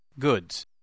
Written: goods

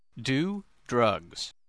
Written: do drugs

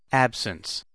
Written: absence

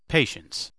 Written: patience

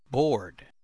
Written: board

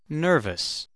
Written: nervous

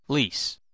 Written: lease

